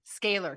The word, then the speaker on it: Scalar: This conversation about it, the energy, scalarler, I'm like blurting it out again. Say it out loud. – Scalar energy. – Scalar, because I was going to mispronounce it again.